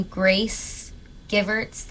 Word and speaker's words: Grace Givertz: But my Bandcamp is my name, Grace Givertz.